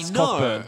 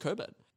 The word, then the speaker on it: Cockburn: Oh, like C-O-Q-U-E? – It is spelled C-O-C-K. – Well, that's Cockburn. That's Cockburn. – I know, and they're obviously just trying to cover it up, being like, no, it's pronounced Coburn. Can't have a silent C and a silent K.